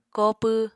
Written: kopw

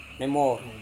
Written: nemor

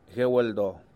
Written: GueuLdoo

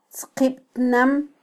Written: tzqibtnam

